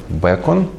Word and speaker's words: Бэкон: Фрэнсис Бэкон, Бэкон, ну как бекон почти, да, только через «э», по-английски так и пишется, и Томас Хоббс.